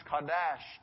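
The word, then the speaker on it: kadash (קָדוֹשׁ): And the word used there for holy is pronounced kadash, and it means holy, other, consecrated, set apart, and sacred.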